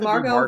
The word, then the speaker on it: Margot: Just it's Margot Robbie. The T is silent.